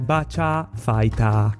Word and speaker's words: Virtua Fighter: In giapponese è scritto Bacha Fighter. Quindi loro lo pronunciano Bacha Fighter, non eh, in altro modo. Loro sono molto precisi la pronuncia. Se quella è la traslitterazione per loro è Bacha Fighter fighter, puoi arguire quanto vuoi con loro, ma non c'è verso.